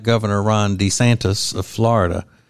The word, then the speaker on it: DeSantis: DeSantis.